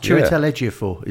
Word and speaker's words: Chiwetel Ejiofor: Chiwetel Ejiofor is in there as well.